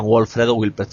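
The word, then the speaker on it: Wolfredo Wildpret: Como Alberto Brito, por ejemplo, el catedrático de Biología Marina de la Universidad de La Laguna, o Wilfredo Wolpert, que fue además el, perdón, Wolfredo Wilpert, siempre lo digo mal,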